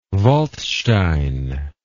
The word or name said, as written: waldstein